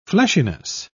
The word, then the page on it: fleshiness: British English